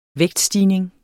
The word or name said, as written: vægtstigning